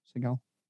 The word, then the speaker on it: Seagal: And I think you get that a lot in Adam Sandler films and Peter Seagal films. You know, they're all, is that how you say his name? Seagal? – Mm-hmm.